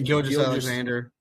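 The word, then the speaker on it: Gilgeous-Alexander: Shea Gilgeous Alexander.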